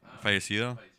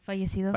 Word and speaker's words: fallecido: Fallecido. – Fallecido.